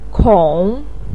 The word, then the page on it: kong: 3